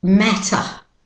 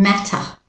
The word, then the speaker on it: matter: Okay, the next one. Matter. Australian British pronunciation. Matter. His partner came. Sorry, it doesn't matter. It doesn't matter. It doesn't matter. Okay, and with the American pronunciation, matter, matter. You can hear the the American It is to matter.